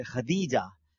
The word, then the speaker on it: خدیجہ: Normally خدیجہ کہتے ہیں لوگ, تا کے ساتھ پڑھ رہے ہوتے ہیں, لیکن ہم جب کتابیں پڑھتے ہیں تو یہ دال کے ساتھ ہے, خدیجہ, حضرت خدیجہ کبرہ.